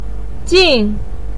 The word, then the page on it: jin4: pinyin